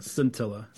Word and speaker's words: Scintilla: Is that saying, am I saying it right? – Scintilla. Yeah.